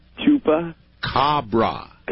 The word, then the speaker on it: Chupacabra: It is my mission in life to teach people how to pronounce that. So let's do it. Say Chupa. – Chupa. – Cabra. – Cabra. – Now say it all at once.